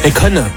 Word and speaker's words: Akon: Monté sur scène, il y avait Akon aussi. – Akon. – Akon, ouais, pardon, je prononce ça à la française. – Akon, c'est pas pareil.